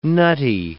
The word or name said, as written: nutty